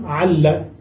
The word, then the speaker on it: لعل: ولهم لعقيل في لام لعل، لهم في لامها الأولى الإثبات والحذف، يعني يقولون لعل بإثبات اللام بوجودها اللام الأولى، ويقولون أيضا عل بدون اللام الأولى، ينطقونها هكذا وهكذا. وفي اللام الثانية أحيانا يفتحونها فيقولون لعل باللام المفتوحة، وأحيانا يكسرونها فيقولون لعل بالكسر، وهي هي.